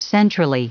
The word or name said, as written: centrally